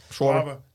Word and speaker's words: Schwarber: I can't pronounce his last Schwarber. – Name. – Schwarber.